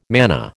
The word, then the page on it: manna: American English